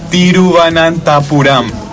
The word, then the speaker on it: Tiruvananthapuram: Ya el pasado 28 de marzo, más o menos, ya hace un poco de tiempo, sí, se produjo en la ciudad de Tiruvananthapuram. – La ciudad súper impronunciable. – Nombrarlo de nuevo. – Tiruvananthapuram.